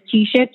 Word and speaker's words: Keisha: My name is Keisha, K-E-I-S-H-A. Last name is Graves, and that is spelled G-R-E-A-V-E-S.